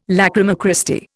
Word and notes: lachryma Christi: Indian

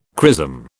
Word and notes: chrism: British English

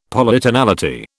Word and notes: Polytonality: British English

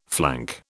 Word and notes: flank: British English

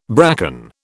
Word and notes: bracken: British English

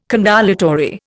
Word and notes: condolatory: Indian

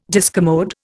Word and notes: discommode: Indian

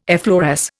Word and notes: effloresce: Indian

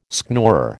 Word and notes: schnorrer: American English

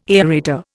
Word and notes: aerator: Indian

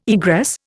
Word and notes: egress: Indian

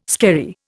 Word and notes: skerry: Indian

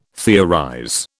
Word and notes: theorize: British English

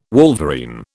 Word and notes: wolverine: British English

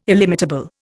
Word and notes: illimitable: Indian

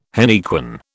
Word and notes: henequen: British English

